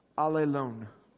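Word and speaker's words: alelon (ἀλλήλων): But the Greek word for one another is spelled, is pronounced alelon, which I think is funny.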